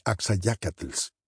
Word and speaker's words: Axayacatl: I don't know any Axayacatls. But by association, I would pronounce it Axayacatl.